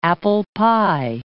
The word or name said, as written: apple-pie